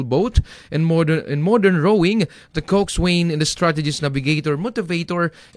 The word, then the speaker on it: coxswain: Ang pagbasa po ng cox ay coxswain, pero pag pronounce po nito ay Coxswain.